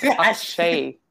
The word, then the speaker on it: Akshay: No, it's Akshay.